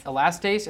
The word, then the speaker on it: Elastase: Elastase, and then she had occult blood.